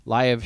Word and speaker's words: Liev: Liv Schreiber's in it, so I'll see it. You pronounce films actors' names. Why don't you pronounce his name bizarre, Liar, or something like that? – Liv Schreiber. – Liev Schreiber.